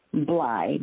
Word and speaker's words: Bly: And pronounce the last name again. – Bly, B-L-Y, which actually means Bly.